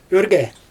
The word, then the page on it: örgE: Xyzyl